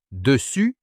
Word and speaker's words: dessus: À l'oreille, « dessus » et « dessous » se distinguent par leur dernière voyelle, celle de « dessus » se prononce comme dans « vu », celle de « dessous » comme dans « vous ».